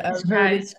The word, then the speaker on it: Cascais: How do you say it again? – I say Cascais, but Cascais. Okay, I say it slightly different.